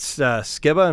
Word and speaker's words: Skiba: You got Matt Skiba, am I saying his name right, hanging out on the record? – Yeah.